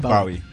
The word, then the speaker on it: Bowie: David Bowie.